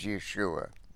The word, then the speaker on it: Yeshua: As mentioned, the Hebrew word for salvation is Yeshua, with the accent on the last syllable, and is a feminine noun, but it is personalized in the masculine form of the Hebrew noun.